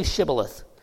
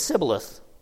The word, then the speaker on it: Shibboleth: When he said no, they said to him, then say Shibboleth. And he said Sibboleth, or he could not pronounce it right.